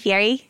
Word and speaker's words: Fieri: So like on Guy Fieri, that's how you say it, Fieri? – Yeah, Fieri. – I've been hearing Fieri. – Fieri. But there is an R in there. Right? – I think it's Italian or something.